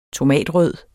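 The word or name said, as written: TOMATRØD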